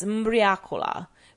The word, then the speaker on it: mbriacola: Locally, it's known as mbriacola, which when pronounced in the local dialect sounds similar to ubriaco, or drunken.